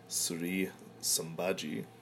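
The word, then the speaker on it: Sri Sumbhajee: Sri Sambaji, actually seeing it written down, that is how you pronounce it, would probably return if it was up to me, but I don't know how I'm going to fit that in there.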